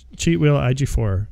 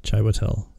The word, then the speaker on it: Chiwetel Ejiofor: Oh, uh, Chiwetel Ejiofor is in this. – Yeah. – Chai Watel. Butchered that. Sorry about that.